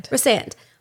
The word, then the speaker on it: Rhysand: Rhysand.